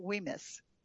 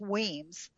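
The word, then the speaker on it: Wemyss: So when you go to the, and it was, I was pronouncing it Weemis, but it's Weems. The Y is not pronounced.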